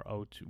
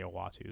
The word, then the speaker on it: Uatu: So Uatu or Otu, who was people pronounce it differently. I say Uatu, the Watcher.